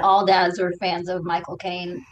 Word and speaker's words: Michael Caine: And they were talking about how to say his name and they say, you say like my cocaine, and it sounds like Michael Caine the way he says it.